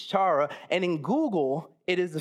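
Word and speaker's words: chara (χαρά): So in the Greek, I immediately wanted to say chara, but that's C-H-A-R-A, and you pronounce that, so it's chara, apparently, right? Somebody? All right, cool.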